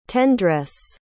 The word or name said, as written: tendresse